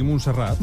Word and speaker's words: Montserrat: ¿Lo he dicho bien? ¿Montserrat? – Sí, Montserrat. – Montserrat, no he dicho bien. Montserrat. – Sí, sí. Montserrat.